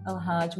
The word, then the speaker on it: Elhajj: So I just want to say, you know, I'm so grateful, so grateful to welcome our special guest, Tima Elhajj.